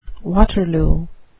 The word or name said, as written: waterloo